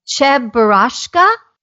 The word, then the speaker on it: Cheburashka: You also collect, now let me see if I can get this right, Cheburashka? Did I say it right? – You're very close.